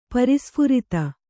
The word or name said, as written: ಪ್ರಸ್ಫುರಿತ